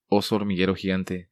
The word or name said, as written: Oso Hormiguero gigante